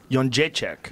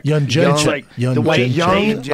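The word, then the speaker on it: Jędrzejczyk: Jonjecek. – Jonjecek. – Like young Jamie? – No. Like Y-U-N.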